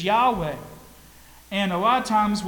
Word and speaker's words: Yahweh: Yahweh is the way it's pronounced in Hebrew. Sometimes in the Old English, we say Jehovah. Whatever it is, whenever you see L-O-R-D in all caps in your Bibles, that's the covenant-keeping God.